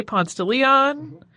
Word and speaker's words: Ponce de Leon: So here in Atlanta, we say Ponce de Leon. That's not really the way you would have pronounced it if you were him.